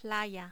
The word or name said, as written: playa